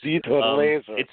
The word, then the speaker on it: Dieter Laser: But I think what makes this movie is, and again, he has his foreign name, so I don't know exactly what the proper pronunciation, but Dieter Laser or Dieter Lasser or Dieter Lesser, whatever Laser. The pronunciation of his name is. – Dieter Laser.